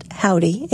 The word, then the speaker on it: Hovvdy: They spell that with a double V, but it's pronounced Howdy, H-O-V-V-D-Y.